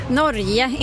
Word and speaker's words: Norge: Men det är ju bra, Karin, och det är ju det här med betoningen, ska vi ta det först? – Aha. – Norge. – Norge, inte Norge, ja.